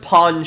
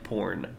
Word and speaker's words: pawn: It's pronounced pawn shop. You're making it sound like porn. It's pawn. Like pawn.